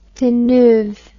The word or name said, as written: Deneuve